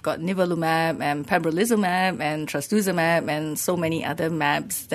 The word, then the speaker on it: trastuzumab: It's called trastuzumab. – Okay. – So trastuzumab.